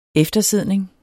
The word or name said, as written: eftersidning